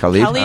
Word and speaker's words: Khaled: So we went from, it was like DJ Khaled or whatever. Is that how you say his name? – Khalid.